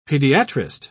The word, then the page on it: pediatrist: British English